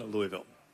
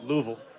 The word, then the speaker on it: Louisville: How do you like to brag on Louisville? – Well, you got to be able to say it right. So you got to say Louisville. – Louisville. – Not Louisville.